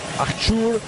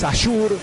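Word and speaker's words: Ashour: Es difícil la pronunciación, porque para mí es Iman. Pero lo escucho Eman, Ashur, para mí es Ashur, pero más o menos nos vamos a estar entendiendo en cómo vamos a pronunciarlo al 8.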